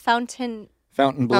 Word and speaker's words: Fontainebleau: How do you say that? – Fountain Blue. – Fountain Blue. – Fountain Blue? Oh, yeah. You don't say it like in a French way? – I guess you could say bleu if you feel like bleu.